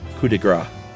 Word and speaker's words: coup de grâce: The Arctic Fox episode is the latest, and Josh said the phrase coup de grace, where he correctly left the P silent, but incorrectly said fat. Gras in French is fat, with a silent last letter, like foie gras, instead of grasse. Silent E, but a soft C. Loosely sounds like grass.